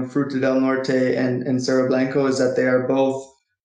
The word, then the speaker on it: Cerro Blanco: And speaking of that, so let's turn now to, is it Cerro Blanco? How would you pronounce this? – Yes, Cerro Blanco, the Cerro Blanco Gold Project in southern Guatemala.